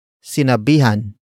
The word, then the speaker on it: sinabihan: Word for word it's sinabihan mo na ba siya.